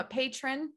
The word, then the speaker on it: patron: So you can become a Patreon Patreon. You can become a patron. Um, sorry, butchered that.